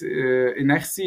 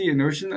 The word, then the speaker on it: inertia: it's inertia, inertion, I think.